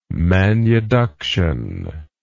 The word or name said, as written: manuduction